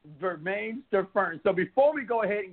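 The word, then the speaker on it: Bermane Stiverne: Bermaine Stavern. Did I correct it? Did I say it right this time? Was the sucker Absolutely.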